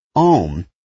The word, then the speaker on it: Om: Om is usually pronounced as 3 separate sounds. A, O, and Om.